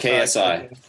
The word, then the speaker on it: KSI: KSI.